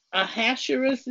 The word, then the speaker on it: Ahasuerus: Ahasuerus? – Oh, go ahead. You know the pronunciation and everything. Oh, Ahasuerus is correct.